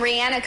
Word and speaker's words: Rihanna: Like rhymes with Sienna. Rihanna. – We've got artists like Taylor Swift and Rihanna competing for that award.